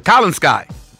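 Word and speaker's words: Kolinsky: Yeah, Kalinskai. – Are we even sure that that's the proper pronunciation? – Let me give it to you. K-O-L-I-N sky. S-K-Y.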